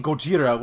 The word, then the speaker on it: Gojira: He's clearly saying Gojira, which is Godzilla's Japanese pronunciation.